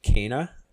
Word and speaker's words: Kena: And I know I've been calling it Kena for the longest time, but apparently the studio Ember Lab confirmed that her name is actually pronounced Kena. So Kena: Bridge of Spirits was announced for a release date of August 24th, 2021.